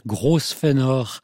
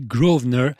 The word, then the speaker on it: Grosvenor: Il a même pu m'arriver de dire Grosvenor en prononçant toutes les lettres de son nom, puisque ça s'écrit Grosvenor, mais je crois bien que ça se dit Grosvenor.